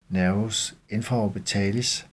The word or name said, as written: n. infraorbitalis